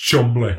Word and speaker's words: Cholmondeley: I do feel sorry for Americans because our place names, it'll be written like Cholmondeley. And you're like, oh, no, it's pronounced Chumley.